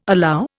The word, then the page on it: allow: Indian